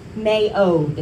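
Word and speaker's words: me'od (מְאֹד): So looking at the Hebrew word strength, it's actually pronounced me'od, and it's the least common Hebrew word we would use for the word strength.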